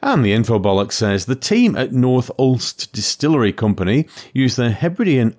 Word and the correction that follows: Uist: I think it's pronounced Uist, but... – Oh yeah, there's an I in that. Yep, it is Uist.